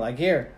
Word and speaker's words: Laguerre: Laguerre.